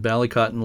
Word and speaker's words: Ballycotton: You spent time at Ballycotton. And again, please correct me if I'm pronouncing it wrong. Ballycotton Lighthouse off the south coast, southeast coast. Am I pronouncing it right? – Yes, you're pronouncing it correctly.